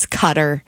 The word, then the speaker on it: Qatar: And then also on here was Qatar, which I say wrong as well. It's Cutter.